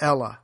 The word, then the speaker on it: Helle: the Ella, spelled H-E-L-L-E, the H is silent.